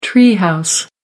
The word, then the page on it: tree house: American English